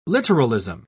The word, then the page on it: literalism: British English